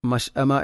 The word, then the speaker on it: المشأمة: മഷ് അമ എന്ന പദത്തിൽ ഹംസയുടെ ഉച്ചാരണം വ്യക്തമാക്കണം. മഷ് അമ والذين كفروا بآياتنا هم أصحاب المشأمة عليهم نار مؤصدة عليهم نار مؤصدة.